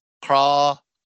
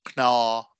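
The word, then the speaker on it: cnámha: But if you want to know the Irish word for bones is either, it's C-N-A-M-H-A, but it's either pronounced craw, Knaw or Croa.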